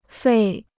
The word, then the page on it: 费: fèi